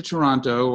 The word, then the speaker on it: Toronto: No, I've been to Toronto, or I know everyone calls it Toronto. Right? – I know. I heard you pronounce the second T.